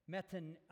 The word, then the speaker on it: metonymy: But before we get there, I want to talk with you about a figure of speech that is in these verses called metonymy.